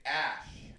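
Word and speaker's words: æ: It's an E-ligature. – E-ligature. – Named Ash. – Ash. – That's how you describe that you're writing it. That's how you say it.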